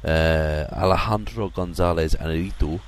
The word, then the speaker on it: Alejandro González Iñárritu: Alejandro González Iñárritu, there you go, that's I believe the exact pronunciation of his name,